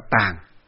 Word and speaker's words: tàng: Ý đây nói là chữ tạng ở đây còn gọi là tàng. Chữ tạng còn đọc là tàng, nó có nghĩa là cái kho.